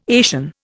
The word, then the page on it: Asian: Indian